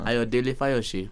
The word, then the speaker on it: Ayodele Fayose: No, let me do it. Uh-huh. Ayodele Fayose. – There you go.